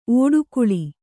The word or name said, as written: ಒಡಕಲು